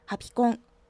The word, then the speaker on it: ハピコン: ハピコン多分同じあの発音としては同じアクセントねハピコンオワコンみんな覚えて